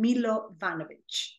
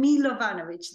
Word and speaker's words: Milovanovich: It's my name and my surname, which is Misha, first name, second name Milovanovich. And that is purely Serbian name in both cases. And I will spell that. That's M-I-S-H-A. And then Milovanovich,